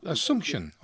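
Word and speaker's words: Asunción: I think it's pronounced Asuncion. – Yeah, Asuncion or something like that, yeah. But yeah. You're quite right. There's one from one. It's Asuncion. Or Asuncion is the capital of Paraguay.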